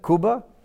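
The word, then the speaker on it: kubba: Kibbeh, kuba. And now I'm saying it right.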